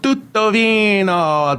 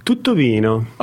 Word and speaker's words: Tuttovino: He is with a company called Tutto Vino. Did I say that right? – Yeah, you did say it right.